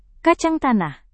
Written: kacang tanah

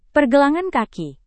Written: pergelangan kaki

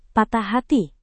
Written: patah hati

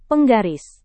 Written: penggaris

